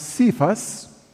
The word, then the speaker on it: Cephas: The Aramaic is Kepha, or it's sometimes pronounced Cephas. C-E-P-H-A-S.